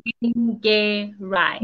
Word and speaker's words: Nyengerai: Take it in threes. Nyengerai.